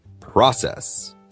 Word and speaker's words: process: 那接着说重音容易错的啊，下面的这一个呢是 Process. – Process,、流程、过程或者处理 Process, the stress 这个重音是在 also the first syllable. – It's also on the first syllable, right? – 对，也是在第一个音节 pro, 对吧 Process, 错误的类型跟我们前面说的那个 mobile 很像的，就是容易放在那个第二个 syllable 就变成 process.